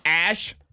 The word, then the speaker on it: ash: We got win, W-Y-N-N, another W sound. Yog, Y-O-G-H. That was a yog. – A ch sound. Ash, A-S-H. That was a long vowel sound like I and fine.